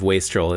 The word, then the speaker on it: wastrel: That's how we pronounce it. That if I may consult the Being Jim Davis style guide, mm-hmm. I believe wastrel is correct.